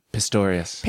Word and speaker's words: Pistorius: Pistorius. – Pistorius.